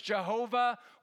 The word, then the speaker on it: Jehovah: Some people pronounce it Yahweh. Have you heard that? Yeah, so they just drop a couple of vowels where it seems to make sense. Another way to maybe pronounce it is Jehovah. Y's and J's kind of interact. W's and V's maybe the same.